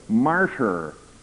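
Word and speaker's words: martyr (μάρτυς): And that's a nice combination of words, because the word witness in the Greek New Testament is pronounced martyr.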